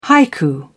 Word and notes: haiku: British English

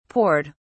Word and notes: poured: British English

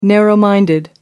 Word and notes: narrow-minded: American English

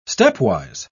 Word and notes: stepwise: British English

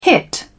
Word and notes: hit: British English

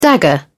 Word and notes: dagger: British English

